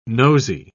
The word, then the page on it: nosey: British English